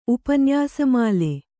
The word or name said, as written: ಉಪನ್ಯಾಸಮಾಲೆ